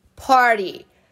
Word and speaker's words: party: A palavra festa party, party. Tá, mas se escreve parte. Se escreve P-A-R-T-Y, parte. Por que, que esse T tem som de R?